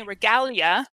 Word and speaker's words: Regalia: We are talking to my friend Anthony Regalia. Second G is silent.